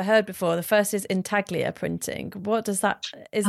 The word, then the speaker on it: intaglio: Yeah, so intaglio, well, it's intaglio if you're Italian. I guess you don't pronounce the G.